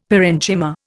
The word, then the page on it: parenchyma: Indian